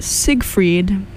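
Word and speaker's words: Seigfried: I was just told this pronounced Siegfried from from Frank Ocean, so at least now I know it's correct.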